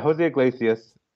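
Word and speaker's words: José Iglesias: Where's that confidence on Jose Iglesias? I think that's how you say it, right? – Yeah, Jose Iglesias.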